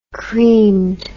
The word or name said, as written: creamed